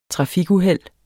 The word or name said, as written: trafikuheld